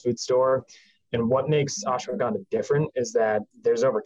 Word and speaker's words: ashwagandha: And we use an ingredient in the product called ashwagandha. It's a mouthful, and it took me a while to figure out how to pronounce it. But ashwagandha, it's